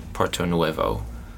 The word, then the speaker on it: Puerto Nuevo: And then our very first episode was some tacos from Puerto Nuevo, if I'm saying that correctly. – You are.